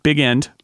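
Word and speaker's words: Bigend: and I'm going to pronounce it in the humorous way, which is Hubertus Big End. Although it is... – Correct. – It is... it can also be pronounced... in a more Frenchified way, because he's Belgian.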